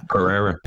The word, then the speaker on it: Pereira: How the fuck did you say? – Pereira. – That's how you say it.